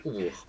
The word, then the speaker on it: Oubykh: Alors ça s'écrit O-U-B-Y-K-H, mais ça se prononce Oubourg.